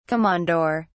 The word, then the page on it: komondor: British English